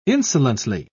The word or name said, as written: insolently